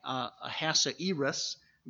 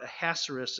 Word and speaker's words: Ahasuerus: Now some say Ahasuerus, but Ahasuerus is the more common pronunciation.